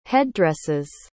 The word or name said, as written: headdresses